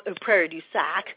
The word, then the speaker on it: Prairie du Sac: So this is Prairie du Sac, however you would like to pronounce it.